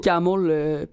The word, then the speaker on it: CAAML: Camol. Puis vous pouvez vous les procurer sur mon site web aussi, camol.ca. – Et ça s'écrit C-A... – C-A-A-M-L.